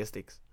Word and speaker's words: Styx: É um S, um T, um Y e um X. – Deve ser Styx, eu diria Styx.